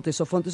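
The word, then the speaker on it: Ctesifonte: Sí, esa ciudad la van a conocer como Tesofonte, se escribe con C y con T, entonces le decimos Tesofonte, que en griego significa lleno de sonido.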